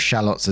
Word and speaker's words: shallot: And before we go into this, I want to clarify on the pronunciation of something. It talks about shallots as in like onions. But I know some places pronounce it as like shallots, I think. But I've always known it as shallot, so I'm going with that, okay?